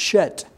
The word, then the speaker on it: Shet: Now in the Hebrew, that's pronounced Shet. Seth, like an S-H there instead of just the S-E.